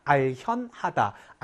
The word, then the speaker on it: 알현: 그리고 요 견자 우리 본문에서도 배웠었는데 뵙다의 뜻으로 쓰이면 현으로 읽어야 한다. 알현하다. 알견이라고 읽는 것이 아니라 높은 사람을 찾아 뵐때 알현한다라고 얘기하는데 그때 현자가 바로 요 모양이다라는 것.